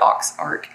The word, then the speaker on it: Aux Arc: And so the French pronunciation, French spelling is A-U-X-A-R-C, and everybody pronounces it Ox Arc.